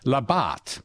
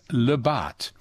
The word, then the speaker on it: La Barthe: It's La Barthe, okay? Point La Barthe, with a French accent. Because Baudin named it.